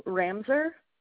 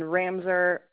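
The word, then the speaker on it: Ramsaur: My name is Noelle Ramser. – Noelle. How do you spell that? No, I mean, N-O-E-L? Yes, ma'am. And then Ramser, R-A-M, as in Mary, S-A-U-R. – S. Okay, can you spell that over, ma'am? S-A-M? – U-R.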